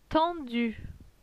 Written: tendu

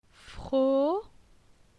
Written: Fro

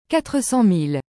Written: 400000

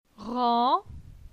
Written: Ran

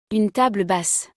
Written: une table basse

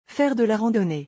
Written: Faire de la randonnee

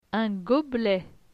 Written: Un goblet

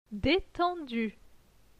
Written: Detendu

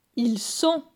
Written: Ils sont

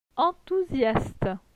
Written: Enthousiaste

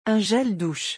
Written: un gel douche

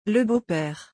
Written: Le beau-pere

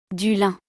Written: Du lin

